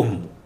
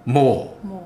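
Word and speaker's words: Mu: 他这个书中说啊，大概在五万年前，太平洋上有一片大陆叫做姆，写作 M-U，但是呢，不读作姆，读作莫莫大陆。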